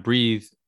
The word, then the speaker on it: breathe: So breath and breathe, obviously the pronunciation is a little bit different, isn't it? You've got breath, which is B-R-E-A-T-H, and breathe, B-R-E-A-T-H-E.